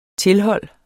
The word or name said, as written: tilhold